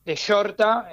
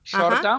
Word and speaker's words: Shorta: Yo soy director creativo de Shorta. Se pronuncia más tipo como un short, sí, pero con A, Shorta.